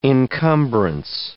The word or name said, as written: encumbrance